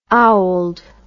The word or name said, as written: owled